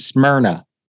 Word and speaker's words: Smyrna: S-M-Y-R-N-A? – Yep. – Amazing. So that's really interesting. – We pronounce it Smyrna. – Smyrna. Perfect. Smyrna. So to the church in Smyrna, I'm sure there are a few churches in Smyrna.